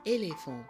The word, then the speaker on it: éléphant: When the E is by itself and it has no accent marks on the top of it, it is pronounced simply like But when the E has the accent marks like what you see on the screen, which is called an accent aigu, it makes the sound of A, like in éléphant, éléphant.